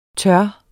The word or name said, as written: turde